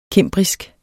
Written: cimbrisk

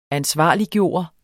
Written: ansvarliggøre

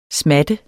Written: smat

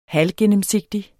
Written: halvgennemsigtig

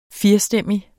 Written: firestemmig

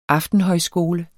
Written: aftenhøjskole

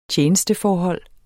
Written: tjenesteforhold

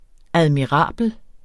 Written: admirabel

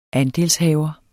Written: ANDELSHAVERNE

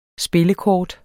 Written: spillekort

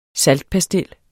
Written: SALTPASTIL

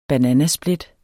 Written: bananasplit

